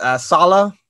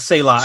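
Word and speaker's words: Saleh: You look at the Jets and Robert Sala. That's what it's pronounced. It's not Selah.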